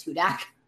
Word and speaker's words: Hudak: It is Hudak.